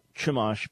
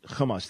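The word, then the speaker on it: chumash (חומש): There is no ch sound in Hebrew, it's a ch. So Robert's question, it's not chumash, but chumash.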